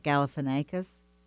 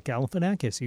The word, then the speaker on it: Galifianakis: And he worked for, I never say his name right, Zach Galifianakis. I did it? – Yes, Zach Galifianakis.